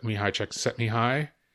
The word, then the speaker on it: Mihaly Csikszentmihalyi: This is, I think, aligned with the research that Mihaly Csikszentmihalyi did.